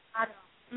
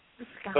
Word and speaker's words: Moscato: Moscato. Mm-hmm. Moscato.